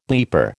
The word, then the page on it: bleeper: American English